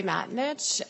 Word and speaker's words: Matonich: Julie Matanich, M-A-T-O-N-I-C-H.